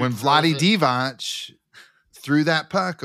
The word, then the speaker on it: Vlade Divac: It's Vlade Divac. That's how he pronounces it.